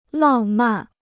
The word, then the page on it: 浪漫: làng màn